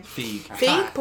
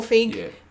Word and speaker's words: Feig: Feig. – Paul Feig.